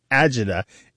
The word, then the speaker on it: agita: Usually these days it is spelled A-G-I-T-A, but you might see it as Argida, A-R-G-A-D-A, or Agida, A-G-I-D-A. And the reason there's some variation in pronunciation and spelling is that for a long time it was only transmitted orally.